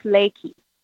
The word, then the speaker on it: Reiki: So the neat thing about Reiki is that it's actually pronounced Reiki. In Japanese, they don't have an R.